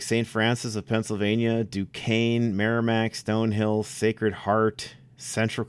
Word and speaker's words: Duquesne: Yeah, Duquesne. – Duquesne. – Yeah, Duquesne, yes, of course. But yeah, it looks like a weak name, but it's Duquesne.